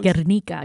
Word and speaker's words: Guernica: It's Picasso's 1937 Guernica, I think it's how it's pronounced. – Guernica, yep.